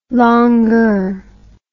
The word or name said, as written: longeur